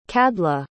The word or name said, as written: cadhla